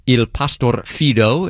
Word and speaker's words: Il Pastor Fido: A suite of music called The Faithful Shepherd from an opera by Mozart, Il Pastor Fido, is how you say that title in Italian.